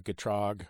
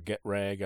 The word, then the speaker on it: Getrag: Those are really the Getrag or Getrag. I've heard both of those. that one pronounced both ways.